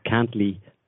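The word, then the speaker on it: Cantlie: He calls himself Cantley, but it's spelled Can't Lie.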